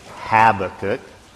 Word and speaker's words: Habakkuk: Some would say Habakkuk. Some would say Habakkuk. If you want to be a little more Hebrewish, you would say Habakkuk. We will put the accent on the second syllable, Habakkuk, primarily.